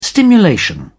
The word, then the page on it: stimulation: British English